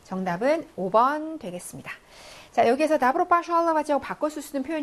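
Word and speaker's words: 나브로 (добро): 나브로, 바샬라바지. 자, 그래서 얘가 오가 들어가고요. 나브로, 강세가 없어서 얘가 아발음 나는 거지 쓸 때는 오라고 쓰셔야 됩니다.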